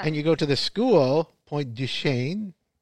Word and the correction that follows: Pointe des Chênes: right? Yeah. Am I pronouncing that right? – Pointe des Chênes. – Pointe des Chênes, okay.